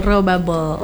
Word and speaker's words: Raw Bubble: Oke, kita di edisi ini ada Raw Bubble, benar nggak sih bacanya? – Betul, Raw Bubble.